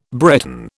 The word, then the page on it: Breton: British English